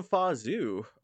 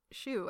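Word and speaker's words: Zhou: I think they call him Fa Zhu, it should be like Shu or something like that. – It should be Shu, I would think, because it's Z-H-O-U when it's anglicized.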